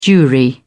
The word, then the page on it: jewry: British English